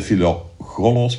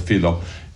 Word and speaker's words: Philogelos: Philogelos, want ik vergeet altijd hoe dat ik het op de juiste manier moet uitspreken. Het is niet de Philogelos, maar de Philogelos. Gelos, ja. Voilà.